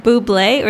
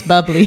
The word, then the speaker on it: bubbly: Is it buble or is it bubbly? – No, it's bubbly.